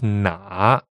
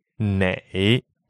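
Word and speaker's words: na (哪): It's na, na, third tone, and it means which. And something important to note here is that there are actually two pronunciations for which in Chinese. Some people say na, some people will say nei. Both are correct.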